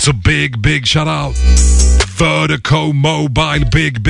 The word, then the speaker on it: VIRDIKO: Vertigo out of Dallas, Texas. Appreciate the love, appreciate the support. And Vertigo is spelled V-I-R-D-I-K-O. That's V-I-R-D-I-K-O. Pronounced Vertigo.